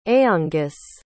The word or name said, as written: aonghus